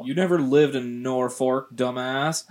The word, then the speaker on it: Norfolk: It's named after the North Fork of the Platte River, so it is pronounced Norfolk. No stress syllables.